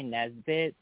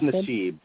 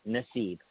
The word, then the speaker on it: Nassib: I want to say Nesbitt, but I think I'm saying it wrong. Nassib? Nassib? – I believe it's Nassib, but... – What the... Okay, Nassib.